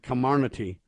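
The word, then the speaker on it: Comirnaty: Camarnaty is the way they want to say it. But all I'm telling you right now is it sounds like a con game to me. Not a Camarnaty. Camarnaty.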